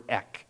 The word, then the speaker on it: ek: And the prefix is the word ek. Kind of like an EK or EC, if you want a hard C.